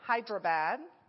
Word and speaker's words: Hyderabad: He is currently in Hyderabad.